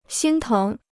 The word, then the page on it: 心疼: xīn téng